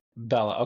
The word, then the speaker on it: Bellah: Bellah, okay, and he's a science fiction author, and he's the one that started the Hardcore History Discord channel.